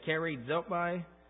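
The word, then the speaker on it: charitomai: And then the last word that we're going to look at tonight is the sixth word here, and this is pronounced charizopai, C-H-A-R, like char almost in English, I-T-O-M-A-I, and so that would be the Greek transliterated into the English.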